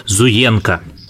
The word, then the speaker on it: Зуенко: Гостем следующего выпуска у нас выступит известный китаист Иван Зуенко. Ударение я произнес правильно, как он сам произносит, Зуенко, да.